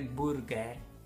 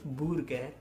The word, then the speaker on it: burger: No se dice burger. Burger. burger.